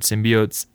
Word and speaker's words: symbiote: It's kind of hard to keep track of all the different symbiotes. We had this conversation last time about how to pronounce this word. Symbiote. – It's symbiote.